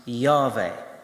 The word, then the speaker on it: Yahweh: And in some translations, that name will be in capital letters, which is translated from the Hebrew word, which is spelt in capital letters, YHWH, which through translation over time gains some vowels and becomes Yahweh.